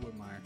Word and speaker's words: Whitmire: Now, is it Emily Whitmire or Emily Whitmore? – Sorry, I did mispronounce that. Whitmire. That's my fault.